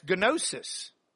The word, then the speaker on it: gnosis: Now, there's another Greek word that's gnosis. Gnosis. That's a long O.